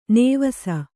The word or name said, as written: ನೇವಸ